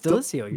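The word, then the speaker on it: Delissio: You got it. It's Delicio. You're saying it right.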